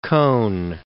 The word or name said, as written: Cohn